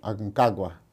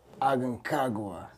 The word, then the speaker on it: Aconcagua: called Aconcagua. – Aconcagua.